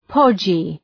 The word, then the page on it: podgy: ‘pɒdʒı